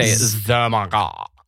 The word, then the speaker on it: ZOMG: Z-O-M-G. – You have to say Zomagod. Like that.